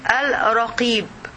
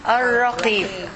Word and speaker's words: Ar-Raqib: Now we will learn about the fifth name of Allah related to His knowledge, which is Al-Raqib. Can you pronounce it with me, sister? Ar-Raqib. Ar-Raqib.